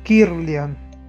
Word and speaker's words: Kirlian: Cámara Kirlian es K de kilo, I-R-L-I-A-N, Kirlian.